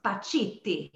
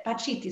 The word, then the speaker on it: Pacitti: I, I think it's Italian and it's Pachetti. – Close. Pacitti. – Pacitti. yeah, perfect. You said it right, man. That's it. Pacitti, Sofia Pacitti.